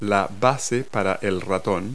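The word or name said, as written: mouse pad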